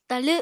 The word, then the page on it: talw: apatani